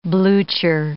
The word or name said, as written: blucher